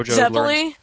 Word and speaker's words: Zeppeli: Yeah, I have a hard time pronouncing his name. Viparelli, the one that JoJo Zeppeli? – Learned. Zeppeli.